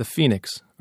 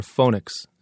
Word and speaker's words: Phoenix: They're all the same Chinese brand, the Phoenix, or Phonix, as locals pronounce it.